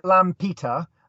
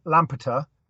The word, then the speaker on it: Lampeter: So you're in Lampeter. Is that, did I pronounce that correctly? – Yeah, if you pronounce it Lampeter and then say it quickly, Lampeter, that would work.